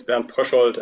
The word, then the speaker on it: Bernd Pröschold: It's Bernd Pröschold, and the book is Reiseziel Sternenhimmel.